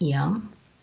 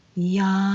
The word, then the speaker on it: yam: The mantra for the heart chakra is YAM, and it's pronounced YUM. And during meditation, the vowel is drawn out like this. YUM.